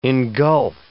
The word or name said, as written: engulf